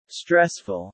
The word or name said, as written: stressful